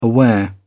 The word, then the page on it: aware: British English